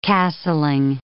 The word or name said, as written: castling